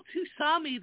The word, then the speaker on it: tsunami: How is it called tsunami, though? It's T-S-U-N-A-M-I. It's a Japanese word. The T is silent.